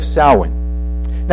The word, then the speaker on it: Samhain: It's spelled S-A-M-H-A-I-N, but it's pronounced Samhain, which is October 31st.